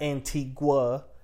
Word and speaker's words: Antigua: pronounced Antigua, not Antigua. It's Antigua.